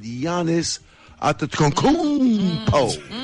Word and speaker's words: Giannis Antetokounmpo: And breaking news, my man from Milwaukee Bucks has ended their relationship with Giannis Antetokounmpo.